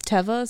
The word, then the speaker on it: Teva: Well, apparently it's pronounced Teva. So who said that? – Teva, the company Teva. Oh. It means like ground in Hebrew.